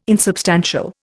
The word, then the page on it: insubstantial: Indian